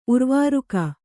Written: ಉರ್ವಾರುಕ

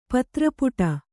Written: ಪತ್ರಪುಟ

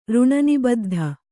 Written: ಋಣನಿಬದ್ಧ

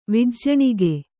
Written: ವಿಜ್ಜಣಿಗೆ

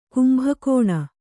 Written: ಕುಂಭಕೋಣ

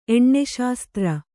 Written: ಎಣ್ಣೆಶಾಸ್ತ್ರ

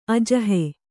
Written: ಅಜಹೆ

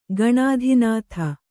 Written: ಗಣಾಧಿನಾಥ

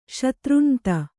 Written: ಶತೃಞಂತ

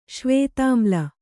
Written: ಶ್ವೇತಾಮ್ಲ

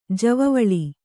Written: ಜವಿವಲೆ

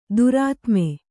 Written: ದುರಾತ್ಮೆ

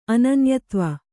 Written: ಅನನ್ಯತ್ವ

